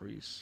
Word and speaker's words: Rhys: R-H-Y-S? – Yeah. – I think that's how you pronounce it. – Reese. – Yeah, it is. Yeah. – It's R-H-Y-S. – Y-S. – Yeah, that's Reese.